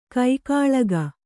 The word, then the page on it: ಕಿಕ್ಕಿಲಿಕೆ: kikkilike